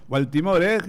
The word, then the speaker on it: Baltimore: Baltimore, ¿no? ¿Se pronuncia así? – Sí, bueno, muy bien. – Baltimore, Baltimore es la palabra exacta.